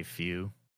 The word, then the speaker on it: Tfue: Have you ever worked out with Tfue? – Never. – Am I pronouncing this right? – I think so. I say Tfue. Some people say Tfue. I say few.